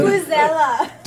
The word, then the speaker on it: vuvuzela: It's like Vesuvela, the horn at the. – Vuvuzela.